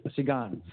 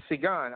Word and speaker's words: Seguin: Lakeview is taking on, who is that, Seguin? How do you say that? – Argon. Seguin. – Seguin. I got to put the specs on. Seguin.